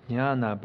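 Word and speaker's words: jñāna: Actually, in Sanskrit, in jnana, but we are going to pronounce jnana. It's easier to say.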